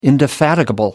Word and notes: indefatigable: American English

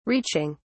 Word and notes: reaching: British English

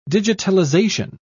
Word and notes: digitalization: British English

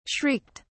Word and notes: shrieked: British English

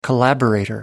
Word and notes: collaborator: American English

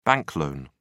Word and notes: bank loan: British English

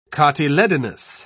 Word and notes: cotyledonous: British English

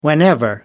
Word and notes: whenever: American English

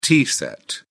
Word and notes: tea set: British English

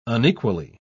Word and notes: unequally: British English

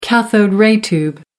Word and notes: cathode-ray tube: American English

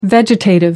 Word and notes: vegetative: American English

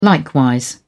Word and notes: likewise: British English

